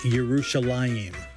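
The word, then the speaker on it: Yerushalayim (ירושלים): In Hebrew, it's pronounced Yerushalayim and literally means possession of peace.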